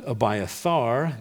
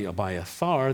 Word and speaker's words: Abiathar: But Abiathar, that is how you pronounce his name in the Hebrew in verse 20, Abiathar, the son of Ahimelech, son of Ahitub, escaped and fled to join David.